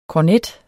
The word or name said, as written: kornet